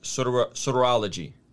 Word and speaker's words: Soteriology: Soteriology.